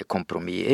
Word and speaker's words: kompromiss: Men er de smarte, så ville jo de òg bruke det som er den tilrådde uttalen, nemlig kompromiss. Grunnen til at folk sier kompromiss, er jo fordi dette er et fransk ord.